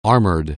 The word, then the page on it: armoured: anglais